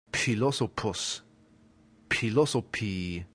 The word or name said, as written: philosophus